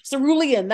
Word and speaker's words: cerulean: teal or as it would be, you know, celurine or whatever. Cerulean, that's I'm, how you say it.